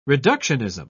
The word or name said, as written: reductionism